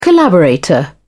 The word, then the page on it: collaborator: British English